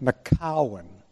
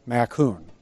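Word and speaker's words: Macoun: Some people pronounce that Macowan. Like one of my daughters-in-law pronounces it Macowan. But we used to pronounce it macoon.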